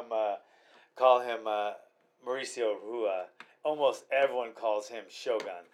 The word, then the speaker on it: Rua: Hua. – Hua. It's an H, but it's Rua. – Yeah. – But we'll say Hua because he's from Brazil.